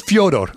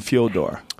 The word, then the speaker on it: Fyodor: We asked Fedor one time, what does your mother call you? He says, Fyodor. I said, we call you Fyodor. We did that on Pride. Everybody, you're butchering his name. His mom calls him like that.